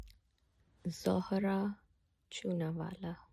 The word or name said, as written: Zohra Chunawala